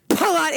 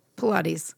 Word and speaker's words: Pilates: Pilates. – Oh, okay. I hear it now. – my emphasis is on the wrong syllable.